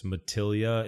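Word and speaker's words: Matilija: But I'm probably saying this wrong for years. Matilla, it's spelled like Matillaja, but like Matilla Canyon.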